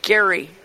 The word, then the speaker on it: Gerry: It should be pronounced Gerry because his name was G-E-R-R-Y.